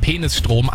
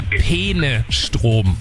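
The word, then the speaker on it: Peenestrom: Und es ist, also ich bin mir sicher, sie spricht es absichtlich so aus, weil sie sagt ja eindeutig Penisstrom, aber es geht um den Fluss Peene und es ist der Penestrom.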